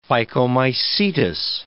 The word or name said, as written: phycomycetous